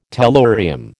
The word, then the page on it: tellurium: American English